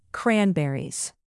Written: cranberries

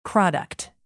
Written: product

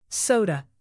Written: soda

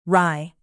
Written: rye

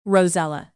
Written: rosella

